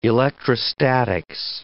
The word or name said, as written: electrostatics